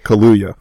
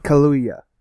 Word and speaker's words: Kaluuya: Oh, okay, Kaluuya. – Kaluuya.